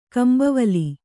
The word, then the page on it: ಕಂಭವಲಿ: kambhavali